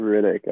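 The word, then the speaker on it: Rittich: We were talking Toronto just traded for goaltender David Ritchie, I believe is how you say his last name. – Riddick, I think.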